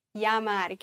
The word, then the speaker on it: llamar: Achtung, hier wieder Doppel-L wie ein J ausgesprochen. Llamar.